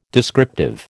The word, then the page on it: descriptive: American English